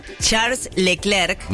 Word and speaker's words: Charles Leclerc: Charles Leclerc.